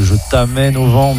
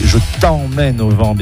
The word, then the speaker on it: Je t'emmène au vent: Le titre, bien entendu, et vous avez rectifié de vous-même, il ne s'agissait pas de Je t'amène au vent, mea culpa, voilà, grossière erreur de prononciation, mais ça, c'est la, voilà, c'est le stress, c'est la première émission, faut essuyer les plâtres, bien entendu, Louise Attaque, c'était avec Je t'emmène au vent, bien entendu, vous aurez rectifié de vous-même.